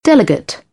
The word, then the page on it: delegate: British English; ɪ